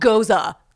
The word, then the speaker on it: Gose: Like, look, it's called Gose. That's how you pronounce it.